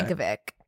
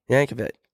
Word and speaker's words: Yankovic: Yankovic? – Yank that bitch, bitch. It's Weird Yankovic? – Al Yankovic. – Okay. Yankovic. Urch. – That's good. – It's definitely, yeah, ah, long A. – Yankovic.